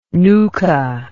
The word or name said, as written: nucha